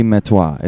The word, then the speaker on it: Metoyer: Rusty Metoir is how he pronounced his name. Metoir. M-E-T-O-Y-E-R. – Metoyer. – You could say Metoyer, I guess, you know, phonetically. But Frenchly, it's Metoir, apparently.